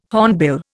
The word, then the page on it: hornbill: Indian